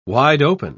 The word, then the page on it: wide-open: British English